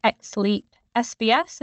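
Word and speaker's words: XLEAP-SBS: On the technology, a big leap in Illumina's technology has been the XLEAP SBS. Is that right? Is that how you pronounce it properly? – Yeah, perfect.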